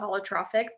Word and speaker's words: holotropic: And so, and I just did the holotropic breath work. – Oh, yeah. – Before re-butchering that, but no, you're good. That's it. Yeah, you got it.